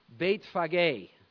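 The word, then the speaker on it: Bethphage: Now when they drew near Jerusalem and came to, and how do you pronounce this? I will tell you how it is in the Greek, Beit Phage, the house of figs.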